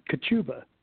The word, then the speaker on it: Kachuba: I tell people to sneeze. It's Kachuba.